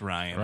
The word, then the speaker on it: Rian: Ian Johnson, Ryan, I don't know how you say it. – I believe it's just Ryan.